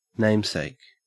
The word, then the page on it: namesake: Australian English